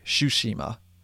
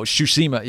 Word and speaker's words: Tsushima: I think you would pronounce that Shushi. Sorry, Shushima. Because TSU in Japanese or anything from Japanese is usually um, it, it's you drop the T basically.